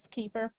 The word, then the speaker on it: Kiefer: Dolores Keeper. – Okay, you said Dolores Keeper. Is that correct, ma'am? – Yes, K-I-E-F-E-R.